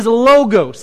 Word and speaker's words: logos: The word is logos. If you want to pronounce it logos, you can, but I'll roll my eyes at you because I don't like that pronunciation.